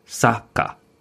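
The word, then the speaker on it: saka (サッカー): But it's spelled, we say soccer, but the Japanese say saka, right? – Saka.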